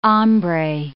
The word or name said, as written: hombre